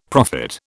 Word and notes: profit: British English

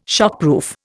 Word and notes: shockproof: Indian

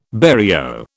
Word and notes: barrio: British English

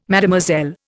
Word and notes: Mlle: Indian

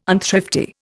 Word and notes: unthrifty: Indian

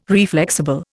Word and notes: reflexible: Indian

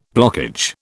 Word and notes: blockage: British English

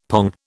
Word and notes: pong: British English

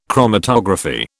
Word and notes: chromatography: British English

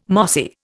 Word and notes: mossy: Indian

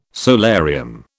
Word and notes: solarium: British English

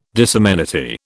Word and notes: disamenity: British English

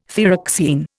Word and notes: thyroxine: Indian

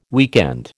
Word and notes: weekend: American English

